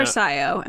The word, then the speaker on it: Sio: I say CO. A lot of people say SIO. CO sounds better to me, so I say that. – I think it sounds like a more Star Wars name, but SIO Bibble sounds funnier. – Yeah, I think maybe that's why I say SIO. – SIO is what's probably supposed to be, but as many people point out, you know, even the characters themselves and even George Lucas himself doesn't pronounce the characters one way.